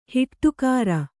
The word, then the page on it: ಹಿಟ್ಟುಕಾರ: hiṭṭukāra